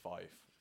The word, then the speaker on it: Fife: That's how you pronounce it, Fife.